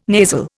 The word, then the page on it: nasal: Indian